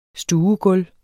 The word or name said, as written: stuegulv